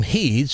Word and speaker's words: Head: Mr. Heed, H-E-A-D, Dick Heed. And I said, he pronounces his last name Heed, so we'll go with that, Dick Heed.